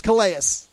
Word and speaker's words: Calais: It's Calais. That's how it's pronounced.